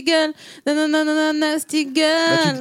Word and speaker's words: Nasty Gal: Nestigal. – Nesti. Ouh, Nesti. – Ah, Nasty. – Ouais. – Nasty Gal. Oui, d'accord, ok, ça y est.